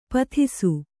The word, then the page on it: ಪೊದೆಸು: kannada